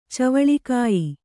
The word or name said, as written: ಚವಳಿಕಾಯಿ